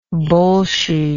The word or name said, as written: bolshiness